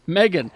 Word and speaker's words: M3GAN: It's not pronounced M3GAN? – M3GAN. Megan. Now, it's written by the guy who wrote Malignant, and that's a scary damn movie.